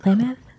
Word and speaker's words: Klamath: I think it's called Klamath National Forest, California. Hope I said that right. – You said Klamath? – Yeah. – That sounds right. – Klamath?